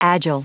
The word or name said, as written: agile